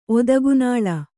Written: ಒದಗುನಾಳ

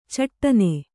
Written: ಛಟ್ಟನೆ

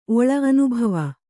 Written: ಓಳಿ

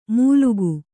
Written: ಮೂಲುಗು

